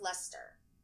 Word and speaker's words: Leicester: The initials of the name Mo, i.e., if they're let's say it's Matthew Owen, and her surname is Lice, is L E I C E S T E R, but it's pronounced Lester.